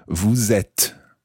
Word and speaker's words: vous êtes: So that's E circumflex, T-E-S, vous êtes. And notice that when you follow vous with être, you pronounce the S of vous, vous êtes.